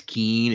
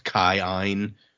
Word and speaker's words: Kiin: It's like a pair of Aons, and so you say kind of both of the Aon sounds, which is why when we read like K I I N as keen, it's actually like pronounced Kaiin.